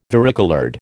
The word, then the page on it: varicoloured: American English